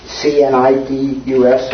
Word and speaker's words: Cnidus: Cnidus, I presume the C is silent.